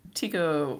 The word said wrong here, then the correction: Taika: Taika Waititi.